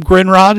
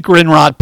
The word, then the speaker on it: Grindrod: And my last name, Grinrod, is spelled G-R-I-N-D-R-O-D. The Grinrod PR Podcast is available on my website, Apple Podcasts, Spotify, Google Podcasts, iHeartRadio, or wherever you get your podcasts.